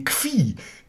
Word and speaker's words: Kvi: Am häufigsten sei ein Und jetzt wird wieder zitiert: hartes, zirpendes, pfeifendes Ki oder Kif. Also K-I oder. Nee, Kvi. K-V-I. – Nochmal, wie schreibt man es? – K-I oder K-V-I.